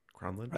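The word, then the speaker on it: Kronlund: Cronlund? – Right.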